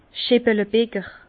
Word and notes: Scheppelebïger: Bas Rhin

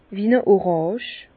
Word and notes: wie-n-e Orànge: Bas-Rhin; Herrlisheim

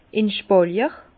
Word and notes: in Spàlier: Bas-Rhin; Herrlisheim

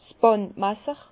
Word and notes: 's Bàndmasser: Bas-Rhin; Herrlisheim

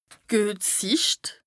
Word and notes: d' guet Sìcht: Bas-Rhin; Strasbourg

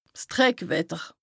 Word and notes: 's Dreckwetter: Bas Rhin; Strasbourg

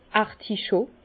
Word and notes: d' Articho: Bas-Rhin; Herrlisheim

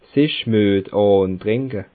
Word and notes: sich Müet àntricke: Bas Rhin; Schiltigheim

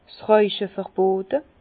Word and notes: 's Rauche verbote: Bas-Rhin; Herrlisheim

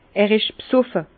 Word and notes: er isch besoffe: Bas-Rhin; Herrlisheim

